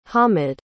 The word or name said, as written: hamid